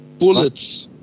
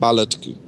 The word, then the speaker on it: bullets: Bullets. Bullets wird nicht Ballet ausgesprochen.